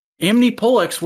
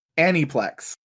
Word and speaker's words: Aniplex: Amnipolex worked on that too. – Aniplex. – I always thought it was pronounced Amnipolex. – That's because that's kind of how they say it at the end.